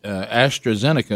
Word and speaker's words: AstraZeneca: And they said they have some concern that AstraZeneca, if I'm saying that right, you are,